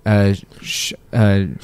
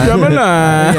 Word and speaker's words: Shyamalan: I don't want to culturally insensitively say his name, so I want to do it right. M. Night Shyamalan. – Shyamalan.